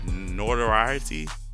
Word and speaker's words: notoriety: No, I'm not saying it right. Notoriety? – Notoriety.